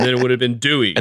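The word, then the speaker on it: Due: Arduino do. – Oh, yeah. Talk to me about but the it's Dewey. Not pronounced do. No, it's Dewey.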